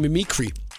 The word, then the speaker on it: Mimikry: Men I hedder åbenbart, hvis man udtaler det rigtigt, Mimikry.